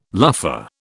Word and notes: luffa: British English

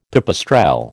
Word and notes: pipistrelle: American English

